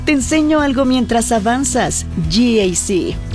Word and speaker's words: GAC: Se escribe GAC, pero se pronuncia GAC. Repítelo, GAC, que el de al lado te escuche.